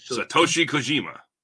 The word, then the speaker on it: Satoshi Kojima: This match, you want to help me with the pronunciation of John Moxley's opponent? Is it Satoshi Kojima. – There we go, yes.